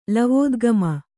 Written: ಲವೋದ್ಗಮ

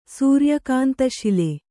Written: ಸೂರ್ಯಕಾಂತಶಿಲೆ

